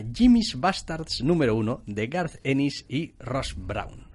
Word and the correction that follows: Russ: Ross Brown, no sé cómo se dice. Russ Brown. – Russ Brown. – Me da igual cómo se dice. R-U-S-S, para el que tenga duda.